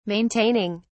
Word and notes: maintaining: British English

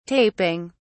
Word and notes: taping: British English

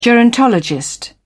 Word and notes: gerontologist: British English

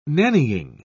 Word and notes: nannying: British English

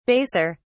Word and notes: baser: British English

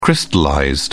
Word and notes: crystallized: British English

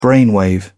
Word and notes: brainwave: British English

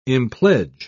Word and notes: impledge: British English

